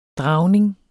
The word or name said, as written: dragning